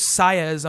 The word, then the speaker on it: Saiz: I also, you know, I've been loving the art, Ivan Rice art has been great, but I don't know, Jesus Saez, I'm going to say, that's how you say his name, S-A-I-Z, really liked it.